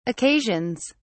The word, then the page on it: occasions: British English